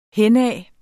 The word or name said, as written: henad